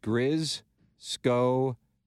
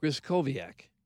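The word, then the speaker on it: Griskowiak: It's pronounced Griskoviak. That's from... – Griskoviak. – Griskoviak. – Well, okay, the W is a V sound. Yeah. Griskoviak.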